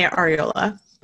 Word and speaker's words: Arreola: So Veronica Arreola is a, did I pronounce that correctly? I normally ask. – I say Arreola, but Arreola.